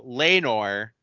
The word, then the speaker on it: Laenor: And then you said his name was, it was Laenor? – That's the way I'm pronouncing it. – Okay, so Laenor, Corliss's son, is on the dragon.